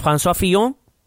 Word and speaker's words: François Fillon: El tercer candidato es eh, François Fillon.